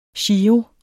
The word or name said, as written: giro